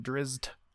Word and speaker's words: Drizzt: So I started the game playing as Drizzt, of course. And that's how they say it in the game. Drizzt. Yeah. I've always, I don't know. Everybody fights about the pronunciation, whatever.